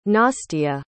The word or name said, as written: nastia